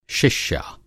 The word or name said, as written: शिष्या